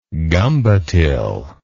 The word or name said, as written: gumbotil